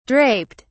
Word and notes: draped: British English

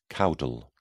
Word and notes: caudal: British English

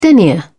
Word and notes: denier: British English

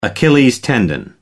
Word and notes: achilles tendon: American English

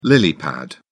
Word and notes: lily pad: British English

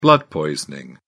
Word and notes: blood poisoning: British English

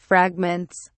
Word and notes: fragments: British English

